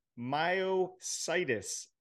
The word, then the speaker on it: myositis: myositis. I said that right, Mike? – You got it.